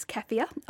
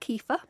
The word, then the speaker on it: kefir: Another option is kefir, or it's sometimes pronounced kefir.